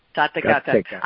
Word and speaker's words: Tathagata: Yeah, the womb of T-A-T-A, Ashwini. – Santa Gata. – Yes, the womb of the, say it again. – Santa Gata. – Tathagata.